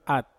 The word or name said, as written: at